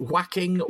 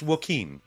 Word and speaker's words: Joaquin: Is it whacking Joaquin Phoenix? I never know. – Why is everybody trying to whack Phoenix all the time? It's not whacking Phoenix. It's Joaquin. It's Joaquin. – Joaquin.